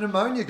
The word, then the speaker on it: pneumonia: Sometimes it's been a silent P, like at the start of pneumonia, guys.